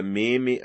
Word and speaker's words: meme: Se isso servir de consolo, podemos pensar alternativamente que a palavra meme aguarda relação com memória ou com a palavra francesa meme. Devemos pronunciá-la de forma a rimar com creme.